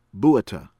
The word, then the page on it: BALFOUR, NGCONDE: KAWN- day BAL- foor